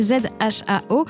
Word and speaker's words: Zhao: Alors d'ailleurs, je prononçais, on en a déjà parlé euh, de Chloé Zhao et je prononçais Zhao puisque ça s'écrit avec, euh, ça s'écrit Z H A O, mais on prononce Zhao.